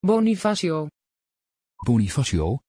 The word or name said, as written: bonifacio